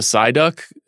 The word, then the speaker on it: Psyduck: It was hard to tell when I saw it because when, say, the Psyduck, am I pronouncing that correctly?